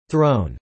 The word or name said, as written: thrown